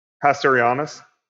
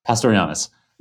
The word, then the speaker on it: pastorianus: Pastorianus. – Pastorianus.